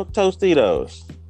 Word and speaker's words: Tostitos: Tostitos.